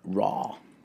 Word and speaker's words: Rauw: Raul. – Raul. – Raul. – Because it's spelled a little weird. – Raw. – R-A-U-W. Raw.